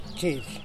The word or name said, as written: txedy